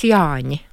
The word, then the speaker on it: Jāņi: Но если говорить правильно, надо сказать Яни.